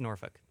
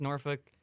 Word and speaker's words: Norfolk: Do you not pronounce the L? – It is pronounced Norfolk. – Really? – I've heard Norfolk. I've never heard Norfolk. – It is pronounced Norfolk. That is what I have always heard from people.